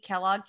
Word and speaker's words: Kellog: My name is spelled Tiffany, T-I-F-F-A-N-I-E, Kellogg, K-E-L-L-O-G.